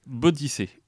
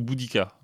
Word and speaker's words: Boudica: C'est Bodice ou Boudica, ça dépend comment on prononce.